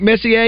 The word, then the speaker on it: Messier: Mark Messier, is that the way you pronounce his name? – Yes.